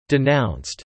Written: denounced

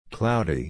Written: cloudy

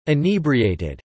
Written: inebriated

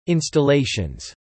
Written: installations

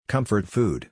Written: comfort food

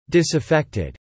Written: disaffected

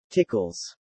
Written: tickles

